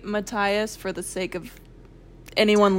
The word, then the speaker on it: Matthias: My copy of Crooked Kingdom has a pronunciation guide in the back for all the names. I looked at it. I was shook. It's Matthias, according to that pronunciation.